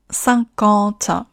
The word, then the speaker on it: cinquante: 最后的 cinquante 有两个鼻音，其余的口型分别是 a 还有 a。在发鼻音的时候，舌头靠后。